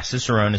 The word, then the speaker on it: Cicerone: Is it Cicerone? – Yeah, Cicerone is how you pronounce it, and it's essentially a beer sommelier is what it is.